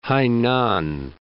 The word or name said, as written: Hainan